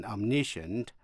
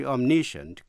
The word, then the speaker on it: omniscient: Chữ omniscient có hai cách đọc. Omniscient có ba âm tiết, đọc theo giọng Mỹ. Và omniscient, bốn âm tiết, đọc theo giọng Anh. Quý vị nghe lại, đọc theo giọng Mỹ. Omniscient. Đọc theo giọng Anh. Omniscient.